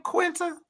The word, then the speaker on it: Quinta: Quinta, how do you say her name? Quinta? – Mm-hmm.